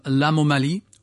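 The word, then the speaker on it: Lamomali: She famously, last year, participated in Mathieu Chedid, his new album, L'Amomali, phonetically one word for the soul in Mali.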